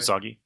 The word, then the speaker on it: Usagi: And 1, Miyamoto Usagi. I think I said that right. – Usagi? Yep.